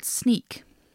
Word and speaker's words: Sneek: So she moved in with her godfather in a village called Sneek. – Oh. – Mm-hmm. Which I was like, is that how you say that? Sure enough, that's how you say it.